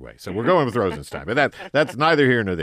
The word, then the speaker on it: Rosenstein: The Freedom Caucus wants to impeach Rod Rosenstein, whose office, by the way, told us a year ago that his last name is pronounced Rosenstein. But I'm gonna bow to pressure. – Oh, everyone says Rosenstein. – I know. Well, we called the office, and I talked to Mr. Rosen, whoever is assistant. How does he pronounce his last name? She said, without any doubt, Rosenstein.